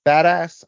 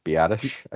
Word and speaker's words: Biadasz: Center Tyler Badass. I never remember how to say this guy's name. – Biadasz, I think.